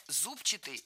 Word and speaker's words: зубчатый: Мы услышали прилагательное «зубчатый» с ударением на первом слоге. И это правильный вариант. Но правильным он стал совсем недавно. Раньше нужно было ударять второй слог «зубчатый».